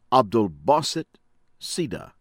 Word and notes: Abdulbaset Sieda: American English

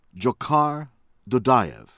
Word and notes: Dzhokar Dudayev: American English; from Chechnya

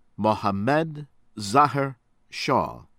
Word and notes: Mohammed Zahir Shah: American English

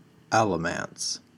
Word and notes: Alamance County: American English

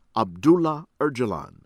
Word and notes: Abdullah Ocalan: American English

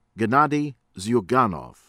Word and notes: Gennady Zyuganov: American English; from Russia

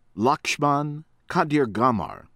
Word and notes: Lakshman Kadirgamar: American English; from Sri Lanka

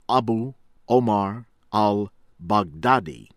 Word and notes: Abu Omar Al-Baghdadi: American English; from Iraq

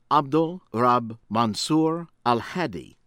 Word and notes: Abd Al-Rab Mansur Al-Hadi: American English

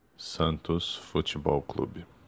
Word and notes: Santos futebol clube: ptbr

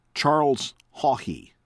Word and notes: Charles Haughey: from Ireland

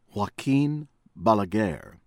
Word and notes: Joaquin Balaguer: American English; from Dominican Republic

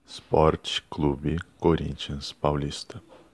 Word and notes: Sport Club Corinthians Paulista: ptbr

